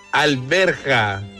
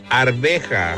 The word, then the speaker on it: arveja: No se dice alberja, se dice arveja.